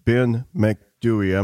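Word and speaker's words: Ben Macdui: Now we're going from Green Children to The Big Gray Man of Ben McDewey. Am I pronouncing that name right? – Yes.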